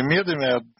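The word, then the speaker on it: Медеме: Говорим мы о Владимире Медыме. Я исправляюсь с ударением. Вот о Владимире Медыме, одном из лидеров еврейского рабочего движения.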